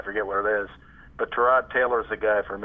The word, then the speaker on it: Tyrod: I didn't have to do too much thinking after I realized the value of Tyrod Taylor. Now, we learned a lot about Taylor during the training camp. One, as we learned how to pronounce his name, it's Tyrod, not Tyrod.